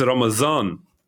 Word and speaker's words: Ramadan: But it's fascinating because like even the word Ramadan is like one of those words that's pronounced differently, in that the D sound in that is a Z sound in certain dialects, in certain rural dialects, also in the Persian Persian it's Ramadan, yeah.